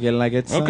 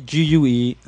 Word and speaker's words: Guelaguetza: Guelaguetza. – Okay, that's good. – It's I always tell people when they're reading it, the U is silent, and that helps everybody. – Yeah. – Okay. Guelaguetza.